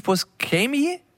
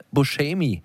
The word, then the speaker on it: Buscemi: Schrecklicher Titel, ein Film mit Steve Buscemi. Ach, ehrlich, so wird er ausgesprochen. Wir waren uns gerade unsicher. Aber viele sagen ja Buscemi. Und das ist natürlich völlig falsch.